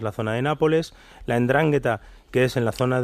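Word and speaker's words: 'Ndrangheta: Vale, una ni siquiera la pronunciaba bien, es Endrangheta. En, endrangheta, sí.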